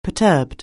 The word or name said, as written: perturbed